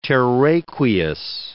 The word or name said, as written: terraqueous